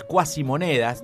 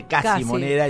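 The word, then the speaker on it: cuasimoneda: Fue en la época de las cuasimonedas, donde cada provincia... Cuasi, sí. Nunca entendí por qué no se dice casi moneda, claro. Se dice cuasi moneda, pero yo repito, sí.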